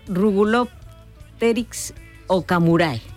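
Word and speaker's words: Rugulopteryx okamurae: David, ¿cuántos años hace que este alga invasora, a ver si lo digo bien, Rugulopteryx ocamurae, más o menos? – Sí, perfecto.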